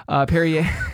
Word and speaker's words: Perrier: No, it is not Perrier. Perrier. – Which rhymes with derriere.